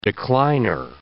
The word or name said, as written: decliner